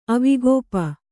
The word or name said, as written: ಅವಿಗೋಪ